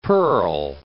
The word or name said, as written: Perl